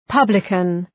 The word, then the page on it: publican: {'pʌbləkən}